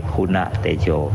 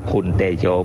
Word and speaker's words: คุณะเตโช: คุณะเตโชถ้าแปลแต่ว่าคุณนะแต่ถ้าเราอ่านว่าบาลีว่าคุณเตโชมันผิดมันผิดรูปผิดรับถ้าเราอ่านว่าคุณเตโชผิดรับต้องว่าคุณะเตโช